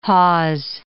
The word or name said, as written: hawse